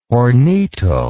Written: hornito

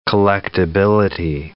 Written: collectibility

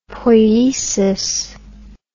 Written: poiesis

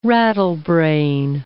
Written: rattlebrain

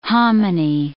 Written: hominy

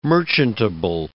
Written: merchantable

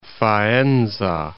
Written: Faenza

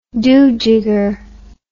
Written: doojigger

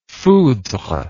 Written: foudre

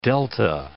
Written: delta

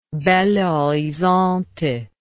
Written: Belo Horizonte

